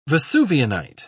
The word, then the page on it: vesuvianite: British English